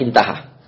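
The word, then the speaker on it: intaha (انتهى): Alif ha itu namanya intaha, bacanya intaha. Selesai.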